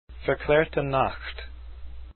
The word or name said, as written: Verklaerte Nacht